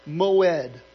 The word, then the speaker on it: moed: In Hebrew, the word for feast is pronounced moed, and it means appointed times or appointments.